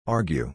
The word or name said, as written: argue